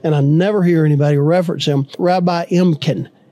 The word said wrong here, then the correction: Emden: Rabbi Emden.